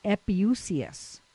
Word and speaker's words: epiousios: The word daily is in Greek epiousios. That's how that's pronounced.